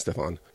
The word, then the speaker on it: Stefan: It could be Stephen, but it's spelled with an F, so I'm saying Stefan.